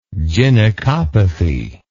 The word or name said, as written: gynecopathy